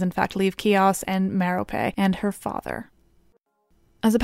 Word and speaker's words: Merope: Now, other versions of this story give the added details that the princess's name was Merope, which I'm pronouncing like in Harry Potter, but is probably more like Merope and that her father,